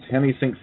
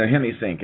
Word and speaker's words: Hemi-Sync: No, HemiSync. It's spelled HEMI-SYNC. – Okay. – Hold up. – I got it now. I didn't understand what you were saying before, but I think I got it. HemiSync. – No, that's okay. I was just making sure I had it right. – Oh, okay. – HemiSync. That last part is spelled S-Y-N-C.